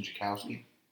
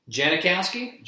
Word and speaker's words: Janikowski: I got made into the playoffs one year because of Sebastian Janikowski. – Janikowski? – Janikowski.